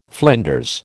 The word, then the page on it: flinders: American English